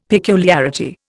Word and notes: peculiarity: Indian

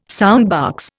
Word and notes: soundbox: Indian

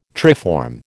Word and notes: triform: American English

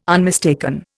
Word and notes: unmistaken: Indian

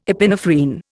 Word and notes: epinephrine: Indian

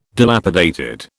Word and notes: dilapidated: British English

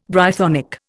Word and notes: Brythonic: Indian

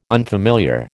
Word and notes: unfamiliar: American English

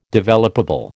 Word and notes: developable: American English

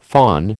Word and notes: phon: American English

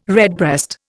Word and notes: redbreast: Indian

